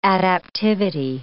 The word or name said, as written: adaptivity